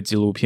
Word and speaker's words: Giloo: 但可是要想想，现在可是在二零二二年这个年代，iPhone 都发行到十四的这个年代，在西太平洋东南方这座小岛上，甚至还有专门针对纪录片的串流平台，叫做呃 Gilo。对，大家好像都念错，G I L O O，大家会怎么念？Gilo。纪录纪录应该是念纪录，就是纪录片那个记录的啊，华语不是华语了啊，闽南语吧，或河洛话，随便你要怎么说，whatever。